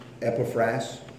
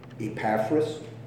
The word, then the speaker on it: Epaphras: Remember, I said it can be pronounced either way. How many of you pronounce that name Epaphras? Two, three. How many of you pronounce it Epaphras? Okay, one, two, three. Okay. So you see, there's different ways you can pronounce them.